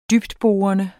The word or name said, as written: dybtborende